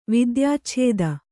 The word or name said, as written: ವಿದ್ಯಾಚ್ಛೇದ